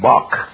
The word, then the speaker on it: Bach: Now, if this letter comes at the end of a word, you pronounce it like C-H, Bach. You spell Bach.